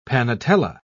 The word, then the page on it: panetella: British English